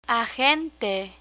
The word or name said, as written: agente